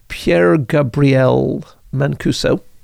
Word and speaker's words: Pierre-Gabriel Mancuso: But around 15 years ago, Professor Sacha Stern and, I hope I'm pronouncing this name correctly, Pierre-Gabriel Mancuso. – No, that's exactly how it's supposed to be said.